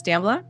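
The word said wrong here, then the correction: Damballa: Oh, Dambala. Yeah.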